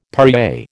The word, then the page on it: Perrier: American English